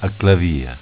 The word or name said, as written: Aclavia